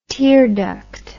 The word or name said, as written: tearduct